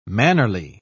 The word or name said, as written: mannerly